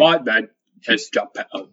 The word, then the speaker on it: Geppetto: Geppetto? Is that how it's pronounced? – Yeah. – Like Cornetto?